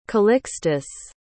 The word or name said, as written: calixtus